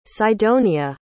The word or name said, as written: cydonia